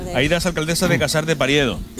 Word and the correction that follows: Periedo: ¿Lo he dicho bien? – Periedo.